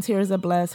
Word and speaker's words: Tirzah: If you just type in Tirza Bless, her name TirzaH, and it's pronounced Tirza Bless, spelled BLESS.